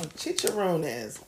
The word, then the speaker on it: chicharrones: Chicharrones. – That's how you say it in Spanish.